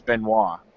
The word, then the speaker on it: Benoit: It's actually the French pronunciation of it is Benoit.